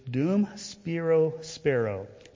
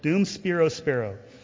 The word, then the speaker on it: Dum Spiro Spero: In Latin, it is Dum Spiro Spero. Nobody really knows how to pronounce Latin, so that's the best. That's what I'm going to give you there. Dum Spiro Spero.